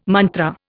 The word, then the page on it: mantra: Indian